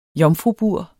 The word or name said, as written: jomfrubur